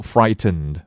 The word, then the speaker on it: frightened: 接下来第二个单词，frightened，我们也可以读成 frightened，把 t 的音堵回去，frightened，或者正常读 frightened，两个都可以。